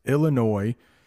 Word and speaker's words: Illinois: I know there's an S, but it's pronounced Illinois.